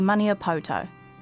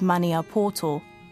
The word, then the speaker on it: Maniapoto: Waitomo is located in the area of our iwi, Ngāti Maniapoto. As I'm talking to Mum, she corrects my pronunciation of Maniapoto because I say the O's the Pākehā way.